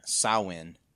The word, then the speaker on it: Samhain: I'd say because the standard one is Samhain. – So we'll do Samhain then? – Yeah, I think Samhain. – Okay. – Samhain it seems like the standard Irish pronunciation.